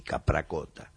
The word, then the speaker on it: Capracota: Como ya escucharon y no se dieron cuenta, lo decimos, este venía de Italia, de un pueblito llamado Capracota, que se debe pronunciar más o menos así, Capracota, en la región de Molise, cerca de los Abruzos.